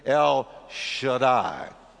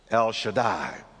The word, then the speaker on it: El Shaddai: And I kind of did a little transliteration there to show you how you would pronounce it. So can you say with me, El Shaddai? El Shaddai. That's how you say it.